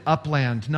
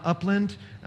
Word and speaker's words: Upland: And so if we lived halfway between Anderson and Upland, where Taylor is, and they pronounce it Upland, not Upland.